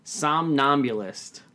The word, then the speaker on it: somnambulist: Somnambulist? – Somnambulist.